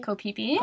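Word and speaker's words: Ko Phi Phi: So this is in Ko Fifi. Ko Pipi. Ko Pipi. It's my It bad. looks like Ko Fifi, but it's actually pronounced.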